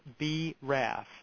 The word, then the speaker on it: BRAF: a gene called BRAF, that's spelled B-RAF.